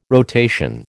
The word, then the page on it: rotation: American English